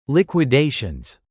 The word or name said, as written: liquidations